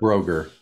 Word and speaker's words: Brogger: Is it Brogger or Broger? – Broger. – Broger. – Ivar Broger. Yeah. – Great. – Is how he pronounces it.